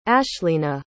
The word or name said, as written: ashleena